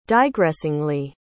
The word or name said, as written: digressingly